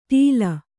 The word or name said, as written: ಟೀಲ